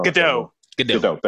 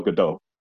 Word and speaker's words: Gadot: Gadot. – Thank you. Gal Gadot.